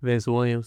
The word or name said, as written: vince WILLIAMS JR